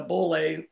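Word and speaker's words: Beaulieu: It's a French name. It's a 16 millimeter camera, film camera, Boulay. – Okay. – Not a Bolex, but a Boulay film camera.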